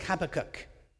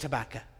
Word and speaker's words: Habakkuk: Looks like Habakkuk, but it's pronounced Habakkuk, trust me.